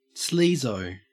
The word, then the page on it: sleazo: Australian English